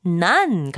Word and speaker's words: none: Fun and none. Fun rhymes with none.